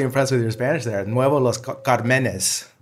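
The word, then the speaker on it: Nuevo Los Cármenes: Nuevo Los Carmenes. – There you go. – Los Carmenes. Yeah. Because there's an accent on it.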